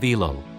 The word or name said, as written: Elul